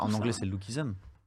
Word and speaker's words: Lookism: Lookism, comment tu prononces mal I'm American. non, c'est Lookism.